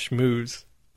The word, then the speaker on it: schmooze: How did it pronounce schmooze? – Schmooze. There it is. That's straight from Google.